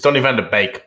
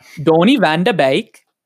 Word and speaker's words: Donny van de Beek: It's Donny van de Beek, by the way.